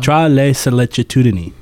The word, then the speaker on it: Tra le sollecitudini: Tra le sollecitudini.